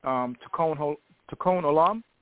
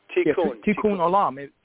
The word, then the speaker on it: tikkun olam: There is a Jewish term, tikkun olam. If I'm pronouncing it correctly, you would know better than... – Tikkun olam.